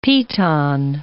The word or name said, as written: piton